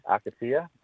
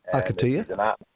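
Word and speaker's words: Akatea: And don't ask me how to pronounce her name, Mick. It's A-K-A-T-E-A. Akatia. – Akatia.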